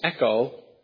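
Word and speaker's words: echo (ἔχω): By the way, the root word is pronounced echo in English, and it means to have and to hold.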